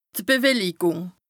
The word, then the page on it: d' Bewellikùng: Bas-Rhin; Strasbourg